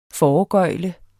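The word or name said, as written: FOREGØGLE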